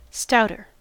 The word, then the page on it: stouter: American English